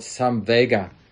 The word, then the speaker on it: samvega: When we do that, what builds in our life is this quality called samvega, which is a Pali word. I think it's the same pronunciation in Sanskrit as well. Samvega, S-A-M-V-E-G-A, samvega.